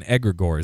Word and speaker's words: egregore: This thing called like an egregore. Is that correct? Did I pronounce it right? – Yes.